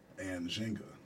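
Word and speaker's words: Nzinga: Anne Zhenga.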